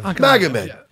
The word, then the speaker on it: Magomed: Magomed.